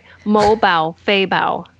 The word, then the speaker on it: Mo Bao Fei Bao: Hold on, it's not right. Mo Bao Fei Bao. Yeah, it's there you go. – There's two bows, no bai.